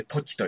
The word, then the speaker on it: 瞬間: 瞬間と書いて時と読ませる。